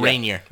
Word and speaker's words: Rainier: There's a kind of coffee here called Mount Rainier. Am I pronouncing that right? – Yes. Mount Rainier.